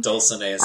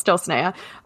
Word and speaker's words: Dulcinea: Dulcinea Septimus. – Yes, Dulcinea.